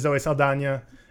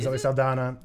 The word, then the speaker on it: Zoe Saldana: Cam, it is Zoe Saldana. It's not Zoe Saldana.